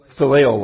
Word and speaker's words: phileo: Verse 16 describes Ruth as a woman of constancy, of filial love, if I'm pronouncing that correct. Phileo love, thank you.